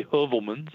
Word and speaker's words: Heuvelmans: So I think it's okay to use the English pronunciation. I say Heuvelmans.